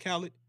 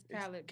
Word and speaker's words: Khaled: Is it DJ Khaled? – It's Khaled.